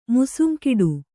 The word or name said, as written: ಮುಸುಂಕಿಡು